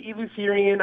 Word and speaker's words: Eleutherian: Did I pronounce that correctly? – I believe so. Yeah, I say Eleutherian. I've heard it both ways.